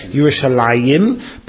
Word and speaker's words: Yerushalayim: We pronounce it as though it's there, Yerushalayim, but if you actually see it in print, you'll see the yud is not there.